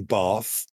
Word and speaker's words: Bath: You've pronounced it correctly if you're from the north of England, because... Oh. There is no R in Bath, which is how us in the south of England pronounce it. There's two ways to pronounce it, Bath or Bath.